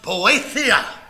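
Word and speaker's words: poetheia (βοήθεια): This is the Greek word pronounced poetheia. Poetheia.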